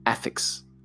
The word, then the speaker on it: affix: Ну-ка, to affix a postscript, сделать приписку. Affix. Подождите, я ударение неправильно делал.